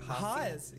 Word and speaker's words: Haas: How do you say it? – Haas. Yeah. – Haas. – Yeah. – Gag. Wait. – It means rabbit in German.